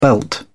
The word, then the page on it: belt up: British English